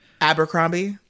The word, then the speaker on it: Abercrombie: Abercrombie.